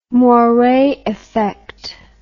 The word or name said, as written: moire effect